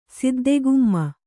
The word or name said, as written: ಸಿದ್ಧಾಗಮ